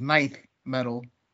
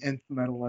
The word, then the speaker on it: Nth metal: So, apparently, N-T-H, the way it's pronounced, is ninth medal.